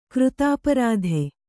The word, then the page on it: ಕೃತಾಪರಾಧೆ: křtāparādhe